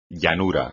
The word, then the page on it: llanura: Spanish